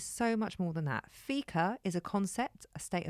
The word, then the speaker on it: fika: So, what better way to take a break from the stress of modern life, clubbers, than by engaging in fika? Is that how you say it? You're doing fika? – Yes.